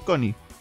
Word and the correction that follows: Sean Connery: para para para para Sean Connery.